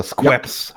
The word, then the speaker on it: Schweppes: Oh my god, the best soda I ever had was Schweppes cream soda from Schweppes, or Squeps, however you want to call it. Yeah, Schweppes is still it's the right pronunciation, but it's SCH, and so I'm talking too much about it.